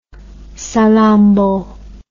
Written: salammbo

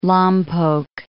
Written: Lompoc

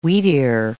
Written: wheatear

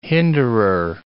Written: hinderer

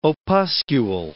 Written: opuscule

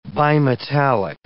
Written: bimetallic

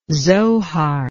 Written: zohar

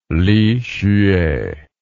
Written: lihsueh

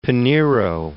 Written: Pinero

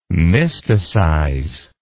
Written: mysticize

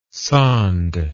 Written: sonde

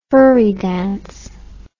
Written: furry dance

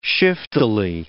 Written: shiftily

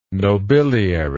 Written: nobiliary

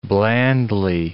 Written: blandly

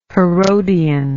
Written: herodian

